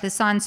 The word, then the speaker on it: Lausanne: Lausanne, Switzerland.